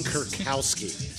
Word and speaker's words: Kurkowski: And it's fucking Kurkowski.